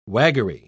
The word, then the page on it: waggery: British English